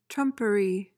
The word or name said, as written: trumpery